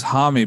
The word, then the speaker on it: Homme: I think it was Homme because it sounded like homie.